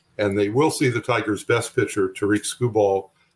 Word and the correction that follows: Tarik Skubal: And I don't even know if I'm pronouncing that correctly. – Tarek Skubal, yes. – Tarek Skubal, so I was close.